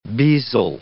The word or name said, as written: bezel